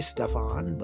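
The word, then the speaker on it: Staffan: spelt S T A F F A N, I would say Stefan, but spelt accordingly.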